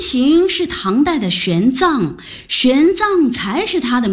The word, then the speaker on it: 玄奘: 我一直以为读作玄奘呢。 – 玄奘的藏上面是强壮的壮的繁体字字形，下面是大小的大，上下合起来是壮大。 – 是的，藏的意思正是壮大，用于人名如唐代的玄奘。